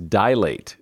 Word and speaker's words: dilate: There's only two syllables in that word. It is dilate, not dilate.